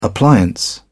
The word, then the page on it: appliance: British English